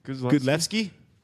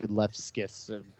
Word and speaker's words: Gudlevskis: Yeah, I feel like I've heard it pronounced Gudlevski, and I've heard it pronounced Gudlevskis. – Yeah, good. I've heard Gudlevskis. It's like, okay, I think you put too many S's in there.